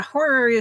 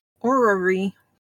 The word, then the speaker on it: horary: Horary astrology. Horary. I can say that word. Everybody adds an extra syllable, which is how it's commonly said, but it's just horary.